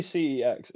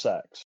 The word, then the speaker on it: CEX: By the way, apparently CEX isn't actually pronounced CEX. It's pronounced sex.